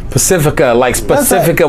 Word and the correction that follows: Pacifico: Pacifico, you can Pacifico.